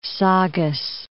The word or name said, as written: Saugus